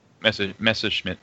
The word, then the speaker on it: Messerschmitt: How do you... – Messerschmitt.